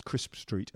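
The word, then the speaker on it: Chrisp Street: Which I've only just found out is pronounced Crisp Street and not Trisp Street, which is how it's spelt.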